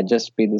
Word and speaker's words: Jaspreet: Now, most of the people, they say Jaspreet or something like that. But yeah, Jaspreet is the right way to pronounce my name.